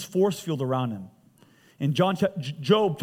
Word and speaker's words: Job: If you've never read the book of Job, if you never knew the Bible, it's Job, J-O-B. But it's pronounced Job.